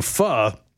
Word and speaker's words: pho: There is a Vietnamese soup that's spelled P-H-O. It's pronounced pho.